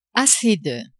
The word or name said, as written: assez de